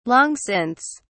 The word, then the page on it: long since: British English